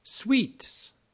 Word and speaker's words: suites: Sweets. Pronounced sweet.